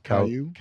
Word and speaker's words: Caillou: Caillou.